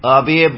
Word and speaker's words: Abhi: Abhi Barthakor. – I need you to spell it. – A-B-H-I.